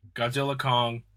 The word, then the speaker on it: Godzilla x Kong: So Godzilla X Kong, The New Empire, apparently said by the director, the X is silent. It's one of the dumbest things I've ever heard. – I know, me too. Apparently it's just supposedly Godzilla Kong, The New Empire.